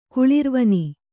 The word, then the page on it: ಕುಳಿರ್ವನೆ: kuḷirvane